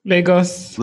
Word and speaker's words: Lagos: Lagos.